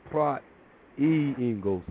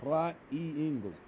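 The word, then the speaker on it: Praat jy Engels: Okay, the U is pronounced E. U is pronounced E. So it's not Prat Uingos. It's Prat. I mean, it's not Prat U. That's what I was saying, Prat U Ingles, Prat E. Prat E Ingles.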